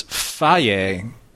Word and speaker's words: Fage: Yeah, see, there's phage, which is the way we pronounce this episode, and then there's Fage, which is the yogurt.